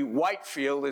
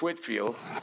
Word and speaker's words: Whitefield: Some people pronounce it incorrectly, Whitefield. It's Whitfield.